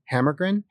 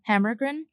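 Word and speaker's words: Hammargren: Because, okay, how do you say his last name? – Hammergren. – Hammergren.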